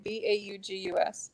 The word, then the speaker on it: Baugus: Um, Christina Kelly, John Burgmeier, Brian, and I always mess up how to pronounce his name. B A U G U S. – Baugus. It's Bryson Baugus.